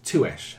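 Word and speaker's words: Tuech: Touche, apparently, is how you say her last name.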